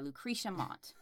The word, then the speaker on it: Lucretia Mott: Yeah, Lucretia Mott. – Okay. – That's how you pronounce her name.